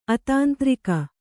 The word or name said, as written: ಅತಾಂತ್ರಿಕ